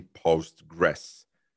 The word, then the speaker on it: PostgreSQL: And it used to be Postgres, but then it was renamed to PostgreSQL.